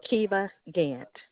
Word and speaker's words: Kiva Gant: Kiva Gant.